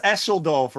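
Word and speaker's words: Düsseldorf: Did he pronounce Esseldorf right? – Yeah, he did, actually.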